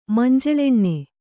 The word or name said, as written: ಮಂಜಳೆಣ್ಣೆ